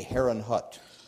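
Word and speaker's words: Herrnhut: We would, as we pronounce it in English, we would say Herrenhut.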